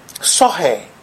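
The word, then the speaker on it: zoe: When it says life there, the Greek word used here is actually pronounced sohe, sohe.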